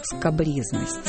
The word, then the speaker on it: скабрёзность: Хотя, если бы носители русского языка помнили о французских корнях этого слова, может, и не произносили его как «скабрёзность». – Да уж, в каком бы контексте вам не приходилось сталкиваться со скабрёзностями, знайте, оно произносится именно так. Скабрёзность, скабрёзный.